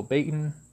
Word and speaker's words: Baigent: Now, there are other books on this family that go into detail, such as Holy Blood, Holy Grail by Michael Baiton, or as you spell it, B-A-I-G-E-N-T, Richard Ley and Henry Lincoln.